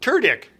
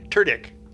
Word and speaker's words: Tudyk: Turdicht. T-U-D-Y-K. Turdicht.